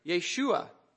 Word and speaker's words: Yeshua: But as best I can determine, in Hebrew, our Lord's name would have been pronounced something like Yeshua.